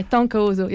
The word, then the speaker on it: Tonka Uzu: Yeah, Tonka Uzu, yeah.